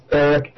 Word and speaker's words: Arik: It's Eric, A-R-I-K, Bjorn, B-J-O-R-N, A-R-I-K.